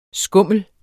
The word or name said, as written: skummel